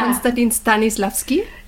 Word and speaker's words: Konstantin Stanislavski: Konstantin Stanislavski.